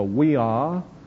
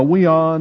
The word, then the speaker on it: via: And there I have put these on the board as the via, the Latin the, when I was going through my 8 years of Latin, was pronounced like a W. And so I'm pronouncing it that way.